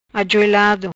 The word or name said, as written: Ajuelado